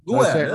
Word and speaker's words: Guerra: I'm getting the, she's understanding the full, like, even the way I pronounce it, slightly Americanized, but. – Oh, okay. That's funny. Guerra. – Guerra, that's right.